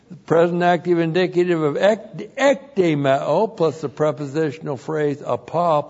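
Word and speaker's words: Ekdemeo (ἐκδημέω): E-K-D long E-M-E long O. Ekdemeo.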